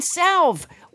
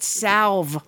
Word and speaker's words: salve: Is salve, that you, it has an L in it. – Yes, that you use the L in salve.